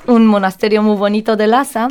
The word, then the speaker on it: Lhasa: Y vamos a pasar a Rincones del Mundo, que hoy vamos a ver un sitio muy bonito del Tíbet, que es Lhasa.